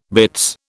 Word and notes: bitts: British English

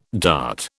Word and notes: dart: British English

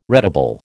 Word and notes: retable: American English

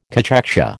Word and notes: cachexia: American English

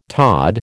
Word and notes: tod: American English

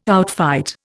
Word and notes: outfight: Indian

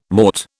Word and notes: mort: British English

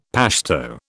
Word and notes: Pashto: British English

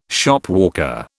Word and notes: Shopwalker: British English

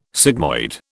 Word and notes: sigmoid: British English

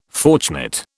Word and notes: fortunate: British English

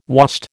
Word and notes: wast: American English

